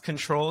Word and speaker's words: CTRL: Former NFL pro and esports coach Amon Green is named, I believe it's pronounced Control, CTRL brand ambassador.